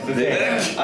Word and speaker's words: Vivek: All right, fine, I'll pronounce the fucking idiot's name right. I like cake, I can get that right. Vivek, I get it.